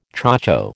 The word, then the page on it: chocho: American English